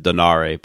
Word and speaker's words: Donaire: So getting back to the World Boxing Super Series for a second, I mean, obviously both the Inoue-Donaire, I always say Donaire. I mean, I've heard people pronounce it Donare, but I'm going to say Donaire.